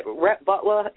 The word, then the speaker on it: Rhett Butler: Rhett Butler in the Gone with the Wind? Am I saying his name right? – Yeah, you said it correctly.